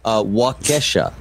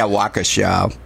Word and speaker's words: Waukesha: Waukesha. Am I getting close? – That's great. That's excellent.